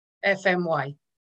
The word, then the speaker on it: Efemaui: I'll teach you how to pronounce that. So you know the letters F-M-Y. So the alphabet, F-M-Y. So you can just do that. If you can just say F-M-Y. – F-M-Y.